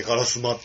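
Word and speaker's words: 烏丸: 丸と書いてカラスマって読むんだ。あ、そうなんですか。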